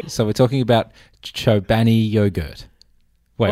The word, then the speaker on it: Chobani: Cholbani? Is it Cholbani? Is that what it is? – Well, it's Chobani.